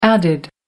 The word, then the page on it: added: American English